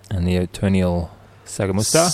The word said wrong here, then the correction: Sagastuma: Sagastuma. Sagastuma. If you don't know how to pronounce that, that's Sagastuma.